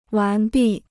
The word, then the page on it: 完毕: wán bì